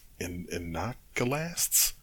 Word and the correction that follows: Iconoclasts: I cannot remember. Iconoclasts. – I would guess Iconoclast. – Iconoclast. Okay, that makes more sense. It's got an O instead of an A, so Iconoclasts.